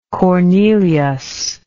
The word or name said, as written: Cornelius